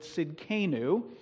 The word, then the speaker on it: Tsidkenu: It's the word Tsidkenu. It looks a little more complicated than it really is to pronounce. And what I like about it is it doesn't have that deep guttural sound that the name we looked at last week had. And when Jordan was teaching, he made quite a point of pronouncing the word Echad correctly.